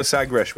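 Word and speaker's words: Sagres: You've got the Sagresh with you. – Oh, he's even got the little Portuguese pronunciation of Sagresh.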